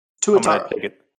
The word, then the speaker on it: Tuatara: Tuatara.